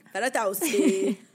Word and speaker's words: Fedotowsky: Oh, how did you say it again? – Fedotowsky. Ali Fedotowsky.